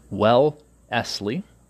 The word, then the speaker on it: Wellesley: Also, was chairman of Foreign Bondholders Protective Council, trustee and president of World Peace Foundation, chairman of Wellesley, and that's pronounced Wellesley College, chairman of the New England Rhodes Scholarships Selection Committee, member of Century Association, and some other things that were Unitarian.